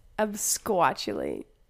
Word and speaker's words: absquatulate: So your word is absquatulate. – Absquatulate. – Yeah, oh. I don't even know if I pronounced that correctly. Dude, no, you did.